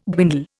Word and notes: dwindle: Indian

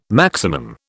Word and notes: maximum: British English

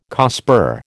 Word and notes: COSPAR: American English

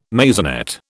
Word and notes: maisonette: British English

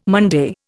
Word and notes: Monday: Indian

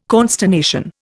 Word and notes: consternation: Indian

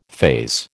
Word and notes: phase: American English

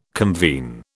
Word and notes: convene: British English

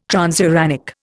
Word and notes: transuranic: Indian

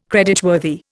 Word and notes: creditworthy: Indian

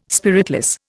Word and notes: spiritless: Indian

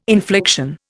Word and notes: infliction: Indian

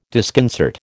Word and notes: disconcert: American English

